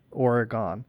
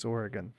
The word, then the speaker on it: Oregon: There's something that they didn't get when they pronounced that Oregon.